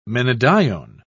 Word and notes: menadione: British English

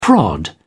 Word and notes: prod: British English

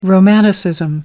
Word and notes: romanticism: American English

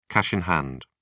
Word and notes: cash in hand: British English

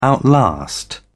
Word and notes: outlast: British English

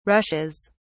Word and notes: rushes: British English

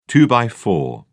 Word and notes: two-by-four: British English

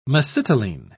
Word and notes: mesitylene: British English

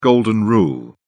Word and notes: golden rule: British English

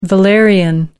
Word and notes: valerian: American English

